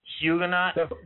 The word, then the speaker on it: Huguenot: and they buried him in I think it's Huguenot? I don't know if I'm saying that correctly. Huguenot? – That's the one. That's it.